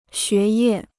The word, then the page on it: 学业: xué yè